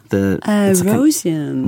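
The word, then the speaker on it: Roscian: Ruskian, is that how you say it? – Rosian. – Rosian.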